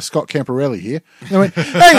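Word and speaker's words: Camporeale: Because I remember once he was on The Footy Show, and they asked him, how's it pronounced? And he said, it's Campo Reale.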